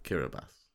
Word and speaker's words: Kiribati: You combine a T and an I together, and you get an S sound. So you have to pronounce the T and the I as S. So Kiribati.